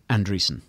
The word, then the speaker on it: Andreessen: Andreessen. Yeah. When you look at his name, it looks like Anderson spelled with a typo in the middle. But yeah, Gavin Andreessen.